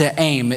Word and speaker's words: te'aim: The word discretion in the same verse is the Aramaic word te'aim, which means decree. That funny little at sign is actually just what you get out of transliteration from ancient Aramaic into the English, but it's pronounced te'aim.